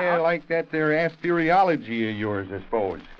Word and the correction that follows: astrology: Eli, how many times have I got to tell you that it's pronounced astrology? – I think Webster says it's astrology, Patience.